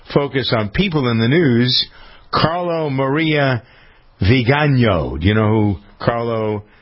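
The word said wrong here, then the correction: Viganò: Vigano. – Vigano, that's how you pronounce it? – Mm-hmm.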